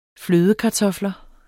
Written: flødekartofler

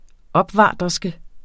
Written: OPVARTERSKEN

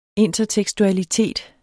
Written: intertekstualitet

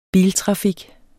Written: biltrafik

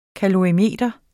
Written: kalorimeter

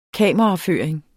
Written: kameraføring

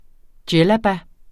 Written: djellaba

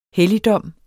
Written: helligdom

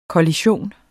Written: kollision

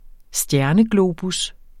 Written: stjerneglobus

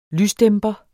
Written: lysdæmper

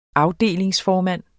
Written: afdelingsformand

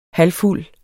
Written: Halvfuld

